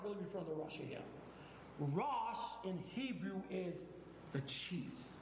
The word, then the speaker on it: Rosh: How do you say chief in Hebrew? The Hebrew consonants. Resh, Aleph, Shin, Rosh. R-O-S-H. Now, you're going to probably say, well, that sounds a lot like Russia.